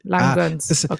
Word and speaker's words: Langgöns: Also ich wusste nicht, ob es Langgöns oder Langgöns, also die G ist doppelt, aber es ist so ähnlich wie Languste. Langgöns.